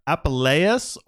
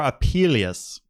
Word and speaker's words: Apuleius: And it was written by a guy named, it's either Apuleius or Apuleius, where you can say it that way. I think I even looked it up, how to say it. And they said, you can say it either way.